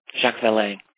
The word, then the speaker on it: Jacques Vallée: Another is what you think of the work of, let me see if I can pronounce this right, Jacques Vallée. – Jacques Vallée.